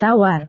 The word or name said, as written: tawar